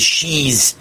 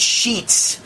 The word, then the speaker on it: Sheetz: And Dave says, I pronounced the word wrong. The convenience store chain that's being sued by the Biden administration is called Sheetz. You pronounce it like Sheetz, but it's S-H-E-T-T-Z. So I thought it was Sheez, but it's Sheetz.